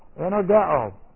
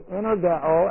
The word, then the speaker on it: energeo: But it's energeo, and I'll just give you the transliteration, E-N-E-R-G-E, long O. That was green. Long O. Okay. N-E-R-G-E-O.